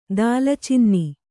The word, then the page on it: ದಾಲ್ಚಿನ್ನೆ: dālcinne; Kannada